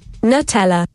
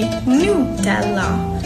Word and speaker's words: Nutella: The correct pronunciation is actually Nutella.